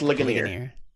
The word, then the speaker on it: Ligonier: So, I was listening to the lecture series on Legionnaire about the Westminster Confession. That's where I heard those. – Legionnaire. – Yeah. – So it's pronounced Ligonier.